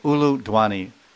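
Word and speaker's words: ulu dhvani: the ladies make this called Ulu Dwani. Ulu Dwani means ooh, like this. It almost sounds like Ulu Ulu. It sounds like the word is U-L-U, Dwani. Dwani means sound.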